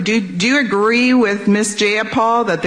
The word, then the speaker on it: Jayapal: Darlene in Connecticut says, I love how Pramila reamed out that horrid pig Debbie Lasko about her disrespectful pronunciation of her name, referring to as Jayapal with a long A multiple times. Pramila called her out, and I paraphrase, if you're going to quote me, pronounce my name properly, it's Jayapal with a long I.